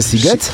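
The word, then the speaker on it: Sziget: kojarzysz, że pisze się Siget, a wymawia się Siget? Siget.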